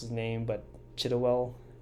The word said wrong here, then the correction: Chiwetel: Ejiofor? – Chiwetel Ejiofor, I think.